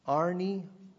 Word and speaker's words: arneomai: The Greek word for deny is spelled A-R-N-E-O-M-A-I. Arneomaia. A-R-N-E-O-M-A-I.